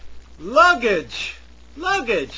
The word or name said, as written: luggage